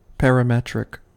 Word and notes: parametric: American English

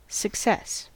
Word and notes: success: American English

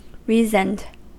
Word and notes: reasoned: American English

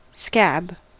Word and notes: scab: American English

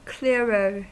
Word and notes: clearer: American English

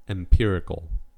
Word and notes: empirical: American English

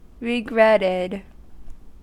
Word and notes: regretted: American English

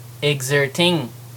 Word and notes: exerting: American English

